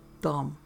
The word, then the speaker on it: dumb: That's not how you spell dumb, dummy, I informed her between peals of guffaw. It has a B at the end, which is silent.